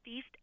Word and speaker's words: Feist: My favorite record out of the year comes from Toronto, Canada, and it's an artist called, I believe it's pronounced Feist, F-E-I-S-T, and the name of the album is Let It Die.